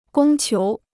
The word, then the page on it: 供求: gōng qiú